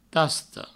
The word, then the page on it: tasta: provençal; tasto